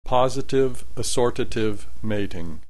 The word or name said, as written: positive assortative mating